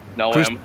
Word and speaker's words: Noem: That's how I say it, Noem. – Christy Noem? – Yeah, like a gnome. – Like a gnome. Like no majority for no marijuana, is that right? Noem? – Or no at all.